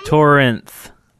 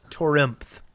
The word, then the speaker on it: Torimth: Redstone Fence Posts by Torinth. Did I say that right? – Yeah. – Okay, a lot of R's. – No, it's Torimth. – Torimth. – Oh, okay.